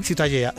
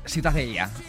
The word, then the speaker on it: Ciutadella: ¿cómo se pronuncia? – La catalán? Ciutadella. – Ciutadella. Bueno, pues Ciudadela también en castellano. – Ciudadela, bueno, sí. – Pues eso, ha quedado clarísimo. Pero, ¿para qué se meten? – ¿Tú cómo lo pronuncias? – Ciutadella. – Mira, muy bien.